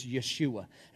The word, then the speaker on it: Yeshua: Yeshua. There's no J in Hebrew, so the way you'd pronounce it is Yeshua.